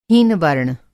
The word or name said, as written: हीनवर्ण